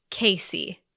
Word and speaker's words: Kasi: I don't know if we've been saying this, but her name is pronounced Casey. – Okay. – Casey. So K okay. K A S I. It's actually pronounced Casey.